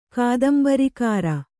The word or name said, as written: ಕಾದಂಬರಿಕಾರ